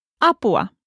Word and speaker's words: apua: In Finnish, help is apua. A-pu-a.